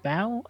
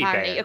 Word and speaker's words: Baio: It says B-A-I-O. – Bao. – Bayo. – Apparently, Bayo. According to the Wikipedia pronunciation guide.